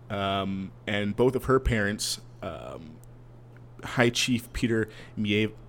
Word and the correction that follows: Maivia: I always mispronounce this. – Maivia.